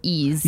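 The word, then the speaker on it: Ys: And for everybody listening, it's Y-S Origin. I never would have known that you pronounce it Ease. – Yeah, it's pronounced Ease, but it looks like Y-S. Ease is it's an old myth. It's a whole long, weird thing. But it's actually, it is pronounced Ease.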